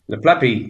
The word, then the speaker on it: Le plat pays: Le plat, you'll have to pronounce it for me. Le plat pays, is it? – Le plat pays, the flat country.